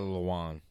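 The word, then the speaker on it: Lewan: To why Lawin, I don't know how to pronounce his last name. – Taylor Lewin.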